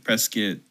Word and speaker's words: Prescott: Yeah, you got to say it right. Prescott. – Prescott? – Yeah. – Not Prescott. – Yeah, Prescott is how it's written, not how it's said.